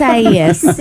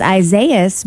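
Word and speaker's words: Isaías: It's Isaias. Well, because again, yesterday I thought it was Isaias because it looks like the biblical Isaiah. – Mm-hmm. – And so I thought Isaias. But instead, apparently. – Isaias. – It's a Hispanic name, mm-hmm. which means that it's pronounced differently, and it's Isaias.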